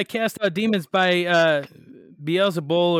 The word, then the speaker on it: Beelzebul: And so he goes on to say, now if I drive out demons by Beelzebub or Beelzebul, Beelzebul. Sorry, I've been saying it wrong the whole time.